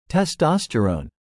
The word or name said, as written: testosterone